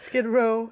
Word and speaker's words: Skid Row: Øhm, som du også fortalte, så ligger hotellet i den del af Los Angeles, som ikke er langt fra Sk- The Skid Road. Row, hvordan man mhm. udtaler det. Skid Row.